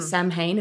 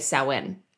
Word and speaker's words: Samhain: It's spelled Samhain. So some people say Samhain if they read it straight out, but most people say Samhain.